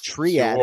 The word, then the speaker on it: Triad: Triad. – Sure. – In our pre-show meeting, like triage.